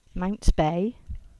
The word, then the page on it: Mounts Bay: English